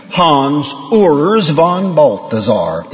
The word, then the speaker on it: Hans Urs von Balthasar: And if you haven't, at least learn to pronounce his name. Hans Urs von Balthasar.